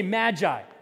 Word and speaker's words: Magi: Here they're called Magi, or very technically, Magi, all right? But in English, it's the soft G, so we say Magi.